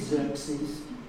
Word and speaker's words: Xerxes: I think it's Xerxes, spelled X-E-R-X-E-S, strange word.